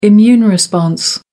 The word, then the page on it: immune response: American English